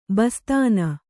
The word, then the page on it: ಬಸ್ತಾನ: bastāna